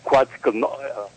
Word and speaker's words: Quetzalcoatl: And in their god, which you call, I can't even pronounce his name, Quetzalcoatl. – Quetzalcoatl said that he will return on that date.